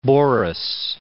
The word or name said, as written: Boris III